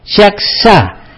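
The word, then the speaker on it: Yaxhá: Otra de las ciudades importantes es Yaxá, que está, esto se escribe con Y-A-X-H-A acentuada, Yaxá.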